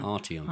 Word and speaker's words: Arteon: But they also brought along a Polo GTI and a Ayrton, which I never know how to say it. Arteon. Arteon? – How do you spell it? – A-R-T-E-O-N. – Arteon. – Arteon. I think it is Arteon.